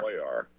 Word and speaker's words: Cuellar: Hey there, I want to correct your pronunciation of the representative's last name. His name is Henry Cuellar. – Cuellar. – C-U-E-L-L-A-R. Yeah, he's Spanish.